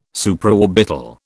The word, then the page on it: Supraorbital: British English